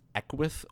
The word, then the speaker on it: Equus: The game opens with you at a kind of a gas station in the middle of nowhere called, I believe it's pronounced Equus Oil, E-Q-U-U-S Oil.